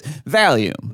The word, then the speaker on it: Valium: Yes, it absolutely is Valium.